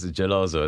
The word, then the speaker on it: geloso: That is actually is geloso is how you say it in Italian.